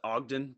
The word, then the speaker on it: Ogden: And is this the right move for head coach Chris Ogan of UT Arlington? – Yes, I think it's pronounced Ogden, but I might be mistaken.